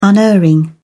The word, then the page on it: unerring: British English